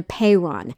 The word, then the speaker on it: apeiron: It's spelled A-P-E-I-R-O-N, apeiron.